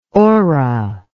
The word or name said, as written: orrow